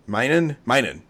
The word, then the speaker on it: Minun: Minun,